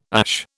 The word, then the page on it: ASH: British English